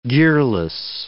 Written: gearless

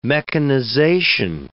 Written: mechanization

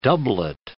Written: doublet

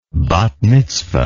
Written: bath mitzvah

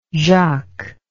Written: Jacques

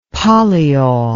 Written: polyol

